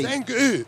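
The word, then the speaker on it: Zengő: Is it Zengo or Zengu Motorsport? How do you pronounce the T? – Zengu. – With the umlaut, the ooh, thank you very much.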